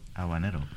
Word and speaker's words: habanero: Habanero. Okay. You don't pronounce H.